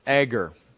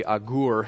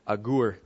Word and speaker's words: Agur: Um, so we're going to call this the God-inspired observations of Egger. Um, actually, it would be Agur in Hebrew if you want to pronounce it like a Jewish guy, Agur.